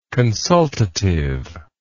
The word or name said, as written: consultative